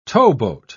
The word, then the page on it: towboat: British English